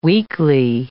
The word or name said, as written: weekly